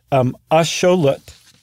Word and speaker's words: axolotl: So the word axolotl, what we call axolotl, is actually axolotl, even though it's spelled the exact same way. In the Nahuatl language, A-T-L is pronounced lut. And X is very often pronounced show.